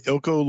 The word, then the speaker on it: Ilco: Did I say that right? – So we're we're calling it the Ilko loyalty program.